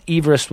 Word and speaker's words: Everest: Now, Sir George Everest's surname is pronounced Everest, with Eve pronounced as the woman's name, but everybody refers to the mountain as Everest.